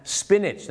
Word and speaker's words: spinach: spinach ไม่ใช่สปีแนทหรือสปีนาทนะฮะ spinach นั่นเองครับออกเสียงว่า spinach cauliflower